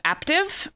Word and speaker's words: Aptiv: So I had heard about this app called Aptiv, rhymes with active, but Aptiv with a P, from three different people.